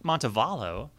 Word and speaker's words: Montevallo: What's really cool is that his album, Montevallo, I could be pronouncing it wrong. – No, I think that's right. – Montevallo has actually sold more than like 11,000 copies in every single week that it's been out.